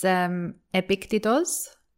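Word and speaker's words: Epictetos (Ἐπίκτητος): Well, in Greek, it's Epictetos.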